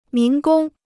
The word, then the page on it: 民工: mín gōng